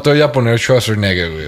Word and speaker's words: Schwarzenegger: Schwarzenegger.